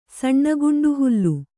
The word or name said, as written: ಸಣ್ಣಗುಂಡುಹುಲ್ಲು